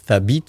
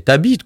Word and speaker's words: Thabit: Donc, Thabit, ça s'écrit T-H-A-B-I-T. C'est un prénom, ça se dit. Sauf qu'ici en France, le son th n'existe pas, tu vois. Tu vois. Donc, du coup, à l'école, c'est t'habites, quoi, tu vois.